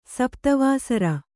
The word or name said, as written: ಸಪ್ತವಾಸರ